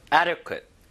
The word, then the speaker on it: adequate: 적정, 영어로 adequate. adequate. 아, 발음이 좀 어려운 게 흠입니다. 너무 좋은 단어인데 적당한이란 뜻이거든요. adequate, adequate. 자, 앞에 힘을 좀 주셔야 되는데 이 D가 살짝 릴 발음처럼 변하긴 변해요.